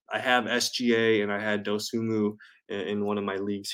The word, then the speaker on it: Dosunmu: I finally learned how to say his name. Ayo Dosunmu. Dosunmu.